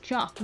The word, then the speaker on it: teach: and one of the words you learn really early on in Irish is chalk, which means house, but it's spelled exactly the same as the English word teach.